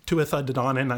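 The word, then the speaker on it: Tuatha Dé Danann: But uh, for now, just be aware that the uh, Tuatha De Danann, I'm going to use the old Irish pronunciation because that's what I'm used to.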